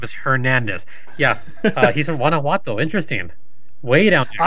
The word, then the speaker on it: Guanajuato: He says, from Beaverton, Oregon, / Guanajuato, Mexico. Did I say that right? – Oh, my God. Okay. – What? – Is it spelled G-U-A-N... A, is it? – Yes. – It's Guanajuato.